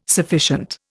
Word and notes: sufficient: Indian

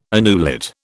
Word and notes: annulet: British English

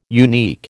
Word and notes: unique: American English

